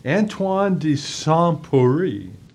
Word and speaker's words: Antoine de Saint-Exupéry: Antoine de Saint-Exupéry.